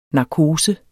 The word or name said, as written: narkose